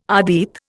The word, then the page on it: adit: Indian